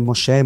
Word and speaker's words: Moshe: Moishi, na verdade, é a pronúncia que a gente tem, mas é Moshe, Moisés.